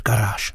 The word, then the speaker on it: Garage: Od roku 93 hrající s upraveným názvem Garage a vyslovuje se to samozřejmě pořád Garage.